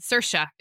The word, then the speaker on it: Saoirse: Saoirse. It rhymes with inertia.